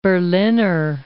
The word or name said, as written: Berliner